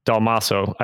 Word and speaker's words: Dalmaso: You can also follow him at Brian Dalmaso. I'm saying that correctly, right? – Dalmaso, yeah. It's funny. Most people butcher it, but you nailed it.